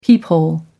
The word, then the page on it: peephole: American English